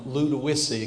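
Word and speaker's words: Ludowici: It's a joy to be here from Ludowice, in case you haven't been pronouncing it right. I had to correct your preacher yesterday and a few of the members already, so hopefully you've got it. If you forget it, just try to say load of whiskey, and that'll help you a whole lot remember it.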